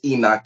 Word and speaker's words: Henoc: My name is Enoch Maurice. It's spelled H-E-N-O-C. If you saw it, you wouldn't be able to pronounce it right. But it's Enoch. The H is silent.